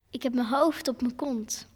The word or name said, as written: Hoofd op m'n kont